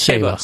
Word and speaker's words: Saba: Well, see, yeah, it's Saba. – Saba. – Saba. Correct. That's how the locals say it. Saba, not Saba.